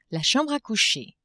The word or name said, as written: chambre a coucher